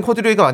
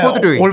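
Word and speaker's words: corduroy: 예 네, 코디로이. 코 예. 그래? 예. 아, 원래는 코듀로이가 맞는데 우리가 사실 골덴이라고 발음하죠 다.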